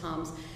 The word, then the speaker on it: Tomes: There's a wonderful book about this by a historian named Nancy Tomes, T-O-M-E-S, it's actually pronounced Toms, and it's called Gospel of Germs.